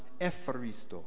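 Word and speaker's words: Epharisto (ευχαριστώ): But we discovered that in modern Greek, Eucharisto is pronounced Epharisto.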